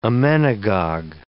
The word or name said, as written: emmenagogue